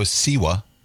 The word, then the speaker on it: Siwa: JoJo Siwa. Is that how you say her name? – Yeah, Siwa.